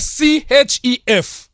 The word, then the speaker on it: chef: So kwa kuandika unaandika C H E F. – Yes. – Lakini kwa kusoma C inabadilika na kuwa S. – Yes, inakuwa chef.